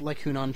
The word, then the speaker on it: Hunan: Like Hunan chicken. – Yeah, like Hunan chicken, yeah.